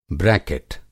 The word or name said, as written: ब्रैकेट